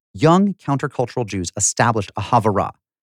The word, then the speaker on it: havurah: called the Cornell Chavura, and she was intrigued.